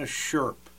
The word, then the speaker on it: Sherp: If you've never seen a Sherp, and it's S-H-E-R-P, not Sherpa, it's not a load carrier in the Himalayas. It's a Sherp, and that's spelled S-H-E-R-P.